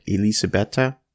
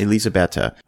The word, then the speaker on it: Elisabetta: And again, we talk about how this film has a cold open where she also plays, am I pronouncing this correct, Elisabetta? – Yes. – Elisabetta, who is the love interest of Dracula when he goes off to fight the Crusades against the Turks.